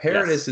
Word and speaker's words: Paradis: Yeah, Paradis yes.